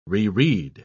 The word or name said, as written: reread